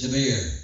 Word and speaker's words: Deber: It is Deber. It is spelled D-E-B-E-R.